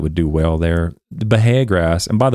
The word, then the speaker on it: Bahia: And if you look it up on Google, and Google, I was pronouncing Bahia grass, but it says Bahia grass, so it's you don't pronounce the H, it's Bahia grass.